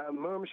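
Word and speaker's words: Mumm: Yeah, and I want to say it's pronounced Moom up north of the border itself, and the border here in Mom, we'll refer to it here as Moom so people don't get confused.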